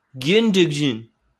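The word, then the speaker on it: Gündoğan: According to German pronunciations, it's going to be Gundogan.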